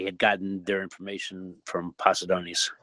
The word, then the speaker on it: Posidonius: It should be Posidonius is the correct way to say that.